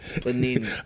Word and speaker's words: Panini: Lil Baby and Gunna with Drip Too Hard, Lil Nas X with Panani. I said that wrong, forget y'all. Mustard with Ballin and Young Thug. – Panani, right? – Panini.